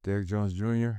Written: Derrick Jones Jr

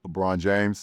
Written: LeBron James

